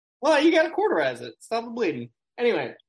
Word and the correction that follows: cauterize: I'm pretty sure that's not how it's pronounced. – Quarterize, yeah, it is. Yeah. – Cauterize.